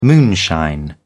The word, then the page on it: moonshine: British English